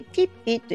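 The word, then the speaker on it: 橘皮: で橘の皮と書いてピッピと呼ばれるんですけれども、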